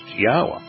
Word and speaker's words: Yahweh: are pronounced Yahweh.